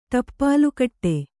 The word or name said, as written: ಟಪ್ಪಾಲುಕಟ್ಟೆ